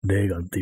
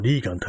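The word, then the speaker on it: Reagan: レーガンっていうと、まあ、本当はあの、リーガンって発音するみたいですけども、まあ日本風に言うとレーガンってなりますよね。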